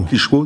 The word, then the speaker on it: Klitschko: Klitschko. Klitschko, c'est ça ouais.